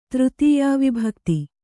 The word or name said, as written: ತೃತೀಯಾವಿಭಕ್ತಿ